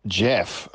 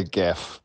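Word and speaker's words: Gef: I think it is Jeff. Although sometimes for clarity, people say Geff, but it is Jeff, yeah.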